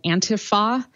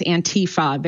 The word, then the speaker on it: Antifa: First of all, it makes me crazy that the way that it's being pronounced is Antifa. There is no long E sound in the word Antifa, but whatever.